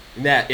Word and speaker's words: Nav: Nav, yes, Nav.